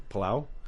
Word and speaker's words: Palau: Now let's go to the next one in the Mariana Palau. How do you say that one again? Palau? – Palau.